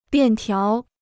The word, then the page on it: 便条: biàn tiáo